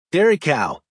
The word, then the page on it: dairy cow: American English